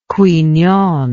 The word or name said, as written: Qui Nhon